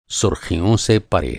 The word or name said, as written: परे